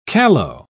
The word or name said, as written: callow